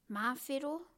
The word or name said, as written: mawhero